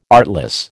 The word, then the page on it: artless: American English